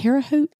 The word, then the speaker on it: Terre Haute: Is that Terre Haute? – Terre Haute.